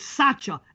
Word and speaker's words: Sacha: His name is not Sacha Cone Baron or whatever the hell it's called. It's Sacha. S-A-C-H-A. If you would have pronounced it correct, we never would have had that fallout. There still is no male Sashas. S-A-S-H-A.